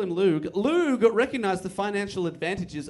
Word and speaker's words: Loog: But then the band got a real manager called Andrew Luge Oldman. And Jones... – Luge. – L-O-O-G.